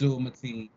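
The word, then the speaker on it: Abdul-Mateen: Or how to pronounce Abdul Mateen.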